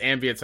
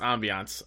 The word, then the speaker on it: ambiance: I also just realized I don't think it's ambience. I think it's ambiance. I said it wrong.